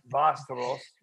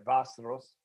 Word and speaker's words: Västerås: Pronounced Västerås, right? Västerås. – Västerås. It's Västerås, yeah. Yes.